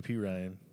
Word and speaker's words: Perine: Whoever this guy is, how do you say his last name? Perron? Whoever he is. – Samaje Perine.